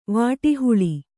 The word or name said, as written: ವಾಟಿಹುಳಿ